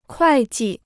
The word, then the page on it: 会计: kuài jì